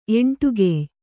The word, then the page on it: ಎಂಟುಗೆಯ್: Kannada